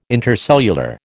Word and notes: intercellular: American English

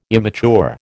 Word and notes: immature: American English

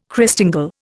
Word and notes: Christingle: Indian

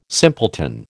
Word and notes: simpleton: American English

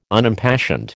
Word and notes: Unimpassioned: American English

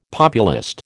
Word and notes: populist: American English

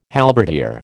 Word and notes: halberdier: American English

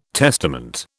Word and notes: testament: British English

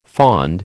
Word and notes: fond: American English

